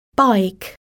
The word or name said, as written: bike